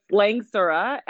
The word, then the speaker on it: Langsura: It's Langsura, and the beginning is Lang. And the end is Sura. So it means pretty in Chinese.